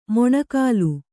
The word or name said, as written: ಮೊಣಕಾಲ್